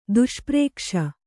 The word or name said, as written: ದುಷ್ಪ್ರೇಕ್ಷ